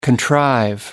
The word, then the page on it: contrive: American English